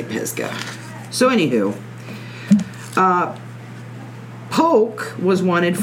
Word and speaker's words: Pisgah: I wonder if they pronounce it Pisgah or Pisa. Because up here in Ohio, they pronounce it Pisa, and it's... – No, like, it's Pisgah.